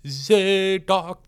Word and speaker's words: Zadok: Handel wrote a piece based on 1 Kings 2 or whatever it is, where Solomon is coronated, and it goes Zadok the priest.